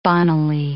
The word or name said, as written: bonnily